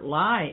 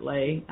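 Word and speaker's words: Ley: This Robert might be Robert Lie. It might be pronounced Lie in Germany, like Heydrich and all those others. – Uh-huh. – But I've always heard it Robert Lay.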